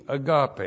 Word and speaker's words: agape: A-G-A-P long E, agape.